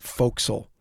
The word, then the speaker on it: forecastle: At 10:45 a.m., the ordinary sailor who had this morning reported the Flying Dutchman fell from the foretopmast crosstrees onto the topgallant forecastle and was smashed to atoms. I know, forecastle is actually pronounced fo'c'sle.